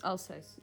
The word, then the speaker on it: Alsace: Alsace.